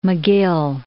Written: McGill

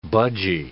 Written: budgie

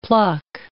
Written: pluck

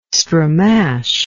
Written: stramash

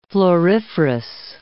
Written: floriferous

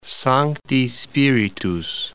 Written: Sancti Spiritus

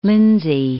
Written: Lindsey, Parts of

